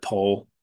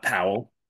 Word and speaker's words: Powell: He's the reason I know to pronounce it Paul and not Powell. – Yeah, if you're wondering, it's spelled, it looks like Pal, but it's Paul.